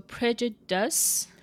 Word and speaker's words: prejudice: Tell me in this word, P R E J U D I C E, how many syllables, where's the stress, and give it a try saying it. So prejudice. So you're going for the last syllable? Try a different syllable. – Prejudice. – Ah, there you no. go. First syllable.